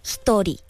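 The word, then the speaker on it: ひとり: 除了音乐好听，它也诞生了很多爆红的梗，像女主角的名字是伊里，日文念作 Hitori。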